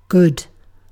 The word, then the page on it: good: British English